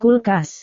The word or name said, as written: kulkas